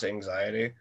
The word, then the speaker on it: AXTY: working with a group from Brazil, they're called, it's spelled AXTY, but it's pronounced Anxiety.